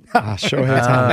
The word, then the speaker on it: Shohei Ohtani: Fernando Tatis Jr. His name rhymes with Mohei Ohtani. – Shohei Ohtani.